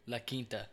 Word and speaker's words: La Quinta: So it says, living in a COVID-free La Quinta, how do you say that? – Q-U-I-N-T-A? – Yeah. – La Quinta.